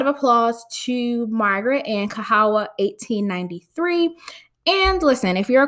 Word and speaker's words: Kahawa: It is K-A-H-A-W-A.